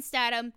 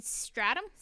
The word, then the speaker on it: Statham: All Asian women I know, literally, like, Asian moms, their number one crush is, like, Keanu Reeves, number one, Jason Statham, number two, The Rock, number three. Except you can't say his name right. – Jason Stratham? – Statham.